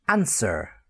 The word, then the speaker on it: answer: Answer, the answering machine, A N S W E R, but you got that A N S E sound, answer.